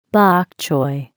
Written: bok choy

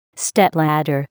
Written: stepladder

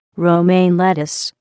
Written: romaine lettuce

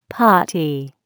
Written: potty

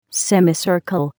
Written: semicircle

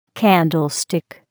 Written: candlestick

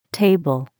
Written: table

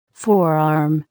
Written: forearm

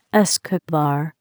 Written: escobar

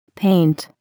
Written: paint

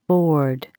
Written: board

